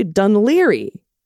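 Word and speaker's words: Dún Laoghaire: Oh, here, look, most North Americans pronounce it Dun Lagarara, but it's actually Dunleary. Oh, okay, so I was close. Rhymes with Sunbeery.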